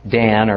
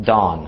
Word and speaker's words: Dan: And the son's name is Dan, or actually properly pronounced Don.